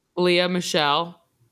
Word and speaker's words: Lea Michele: Lea Michele.